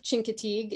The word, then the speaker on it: Chincoteague: So our three destinations were the United States, which was Misty of, how do you say it again? – Misty of Chincoteague in Chincoteague, Virginia.